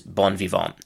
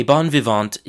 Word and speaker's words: bon vivant: So that's the French pronunciation. English pronunciation is bon vivant. So a bon vivant is literally a uh,